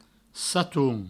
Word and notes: saton: satoun

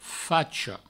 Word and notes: facha: Provençal; facho

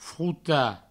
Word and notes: frotar: frouta

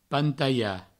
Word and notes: pantaiar: pantaia

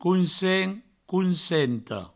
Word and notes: consent: consenta; counsent; counsento

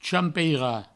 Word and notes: champeirar: champeira; provençal

